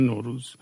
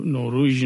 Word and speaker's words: نوروز: مثلا حتی در کردی که به روز میگن روژ به زبان خود کردی، ولی نوروز میگن نوروز. یعنی نوروژ نمیگن.